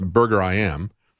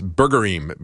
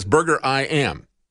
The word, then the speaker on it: Burgerim: They say that it's pronounced Burger I Am. It means many burgers in Hebrew. So I was going to pronounce it as Burgerim because, you know, the word Elohim is plural.